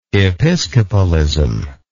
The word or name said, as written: episcopalism